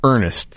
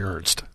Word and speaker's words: Ernst: Let's break down what Ernest said. Josh Ernest. – I heard him say that, but it's actually Ernst.